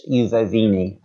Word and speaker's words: Ufovni: It's pronounced Ufovini, and it's spelled U-F-O-V-N-I.